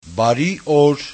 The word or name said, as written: Bari-or